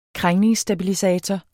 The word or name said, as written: krængningsstabilisator